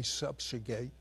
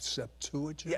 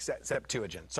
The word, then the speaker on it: Septuagint: Now, when you say Septuagint, are you talking about Septuagint? – Yes, I'm sorry. I'm okay. Saying it wrong. Septuagint, sorry.